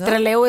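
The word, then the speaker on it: Trelew: Treleu, es que así se llama el lugar en Argentina, en la Patagonia.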